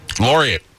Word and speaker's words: laureate: Lariat. – Laureate. – Laureate. – Oh. – That's how you pronounce that.